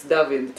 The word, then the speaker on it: David: You know that in Hebrew, every David that you're going to meet, it's David.